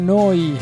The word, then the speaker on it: Neu: Si scrive Neu, si pronuncia Noi.